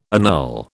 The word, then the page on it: annul: British English